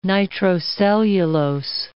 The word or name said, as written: nitrocellulose